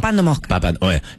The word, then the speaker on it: papando moscas: ...paspando moscas. – Muy temprano. – Se dice así, ¿no? – Paspando moscas. Sí, papando moscas.